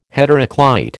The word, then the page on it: heteroclite: American English